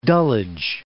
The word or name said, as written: Dulwich